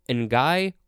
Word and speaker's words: N'Gai: And that is a quote from N'Guy Kroll. N'Guy is spelled capital N, apostrophe capital G-A-I, and Kroll is C-R-O-A-L.